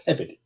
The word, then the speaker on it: Peabody: It's spelled Peabody, but it's pronounced Pappity. Yep. Just like Haverhill is actually Haverhill.